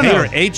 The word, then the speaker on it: Heitor: Is it me or Heitor is like hater? – No, Heitor, H-E-I-T-O-R.